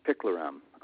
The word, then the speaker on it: picloram: but the main ones are called 2,4-D and 2,4-5-T and picloram. I hope I'm pronouncing that correctly. – Yes.